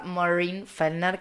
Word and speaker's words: Maureen Fellner: Arroba Maureen Fellner, que se escribe Maureen con doble E y Fellner con doble L.